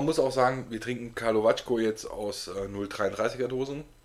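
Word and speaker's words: Karlovačko: Jetzt trinken wir noch einen Kalavako. – Kalavacko. Ich war mal in Kroatien, habe mich gefragt, wie spricht man das aus und dann habe ich es mir gemerkt. – Kalavacko.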